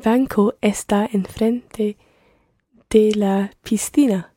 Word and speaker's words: piscina: You pronounce the S as part of the syllable pis, and then the next C is pronounced as a TH, so thina, piscina. – Piscina. – And in Latin America you would hear piscina.